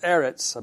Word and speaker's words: Eretz: And that word, that Hebrew word for earth is pronounced Eretz, I believe, E-E-R-E-T-S.